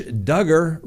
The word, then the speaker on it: Duggar: Josh Duggar rhymes with hugger.